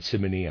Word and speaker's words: Simony: Readers aside, Simony, I'm pronouncing it that way, is S I M O N Y.